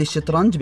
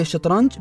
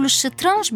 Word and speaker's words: شطرنج: حسناً، سأعلمك، ولكن قل الشطرنج بكسر الشين ولا تقول الشطرنج بفتح الشين. – نعم، سأقول الشطرنج بكسر الشين، ولكن لماذا تلفظ هكذا؟